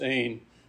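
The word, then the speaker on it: ain (ἦν): It looks like an N and a V. It's pronounced ain.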